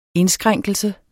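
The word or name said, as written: indskrænkelse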